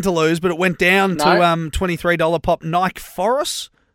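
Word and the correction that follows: Nikaforos: How do you say that? Nike Foros. – Nika Foros. Yeah, hey, just quickly, Ballarat in about 30 seconds time.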